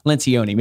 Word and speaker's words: Lencioni: I think in the Advantage book by Patrick, I'm going to butcher his last name, Patrick Liaccioni or Liaccioni. – Lencioni? – Lencioni, maybe. Third time's a charm.